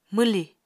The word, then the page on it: mwlì: Apatani